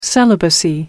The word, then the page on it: celibacy: British English